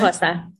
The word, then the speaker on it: Xhosa: really well that Ubuntu is a Xhosa, is that how you pronounce it? – Xhosa, yeah. – Xhosa, a word from South Africa, and meaning that each person's humanity is bound up with the humanity of others.